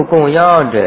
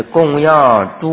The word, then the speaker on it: 供养: 那么这里呢就是供养诸佛，所以读样。